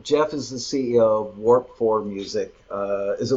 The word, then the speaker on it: Warp IV: Warp 4, it's spelled W-A-R-P-I-V, Roman numeral 4. Roman numeral 4 stands for the fourth dimension, time. So it's a play on words, time warp.